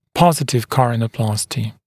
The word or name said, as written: positive coronaplasty